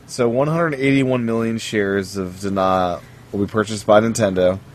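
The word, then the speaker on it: DeNA: So, Nintendo and Dana, or DNA, as it may be pronounced,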